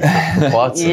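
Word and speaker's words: Capuozzo: That's how you pronounce it, Capuazzo. – Yeah. – I was saying Capuzzo the whole time. Yeah, yeah, there's a lot of people saying Capuzzo, so yeah.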